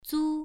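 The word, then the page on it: zu: tone 1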